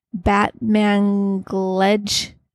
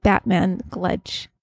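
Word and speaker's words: Batmanghelidj: Replacement of electrolytes and water were found by Dr. I can't say his name, but I'll spell it for you. BATMANGHLEDJ. Batmangledge. Sounds like Batmangledge.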